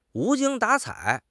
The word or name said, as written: 无精打采